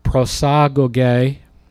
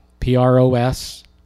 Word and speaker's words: prosagoge: Many times I've taught on this word, and once again, as happened to me frequently, I'm realizing I've been pronouncing it wrong for years. The word access, prosagoge, is the word, prosagoge.